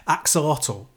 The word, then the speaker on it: Axolotl: a game called Axolotl, which is spelled like AK, as in, I think, AK-47, dash, Zolotl.